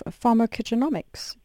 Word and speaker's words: pharmacogenomics: And can you tell us more about the field of pharmacogenomics? Did I pronounce it correctly? – Yes, that is perfect.